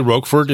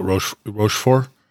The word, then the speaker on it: Rochefort: Comte de Rochefort. We would say Roquefort in America, like the cheese, but in the movie and in the book, they keep pronouncing it Rochefort.